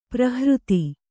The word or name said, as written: ಪರಿಹೃತಿ